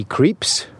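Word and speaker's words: Krieps: One day, he meets Alma, a young woman played by Luxembourg native Vicky Creeps. Didn't know how to pronounce her surname until Paul told me in this podcast.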